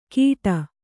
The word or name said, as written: ಗಿಡ